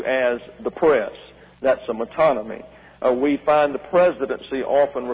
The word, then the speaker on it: metonymy: And therefore, the phrase, the throne, is a metonymy. That's spelled M-E-T-O-N-Y-M-Y, a metonymy.